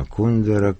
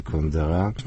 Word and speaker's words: Кундера: И так это в новейшее время к этому прибавился, безусловно, Кундера, которого российские многие даже интеллектуалы предпочитают почему-то произносить с ударением на втором слоге, а некоторые на последнем. Ну, очевидно, от того, что он...